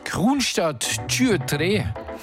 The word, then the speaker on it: Kronstadt Tjuetre: Ja, die nennen sich Kronstadt Tjuetre. Und auf Deutsch ausgesprochen heißt es dann Kronstadt 23, der Titel Huldrik.